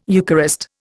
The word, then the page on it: Eucharist: Indian